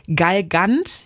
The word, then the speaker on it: Galgant: Du machst erstmal diese Gewürzpaste, da kommen Chilischoten rein, Knoblauch, Schalotten, Galgant. Ich hoffe, ich habe das jetzt richtig ausgesprochen. – Das, ja, das heißt so, so ja.